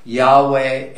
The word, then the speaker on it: Yahweh: The pronunciation of this name is not certain, and there are different theories about how it should be pronounced. The most common pronunciations are Yahweh and Jehovah.